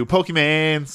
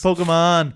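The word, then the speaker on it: Pokémon: Pokemon. – Pokemans. He hates that. – It's not how it's pronounced. – Oh, I know. It's hilarious to me. – There's no S and there's no A, goddammit.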